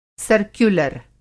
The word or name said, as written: सर्क्युलर